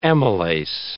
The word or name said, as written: amylase